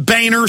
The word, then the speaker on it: Boehner: John Boner Scam, but he pronounces it Boehner, which I've talked to Germans and looked it up. It is Boner.